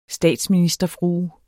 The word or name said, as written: statsministerfrue